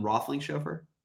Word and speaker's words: Roethlingshoefer: Justin Roethlingshoefer.